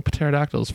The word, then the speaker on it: pterodactyl: So why don't Greek. We pronounce it pterodactyl? – Pterodactyl. – Then? Because then it would be going along with the Greek. – Ptero? – Ptero. – Is how you say that? – Yeah. – So the better question is, why is it silent?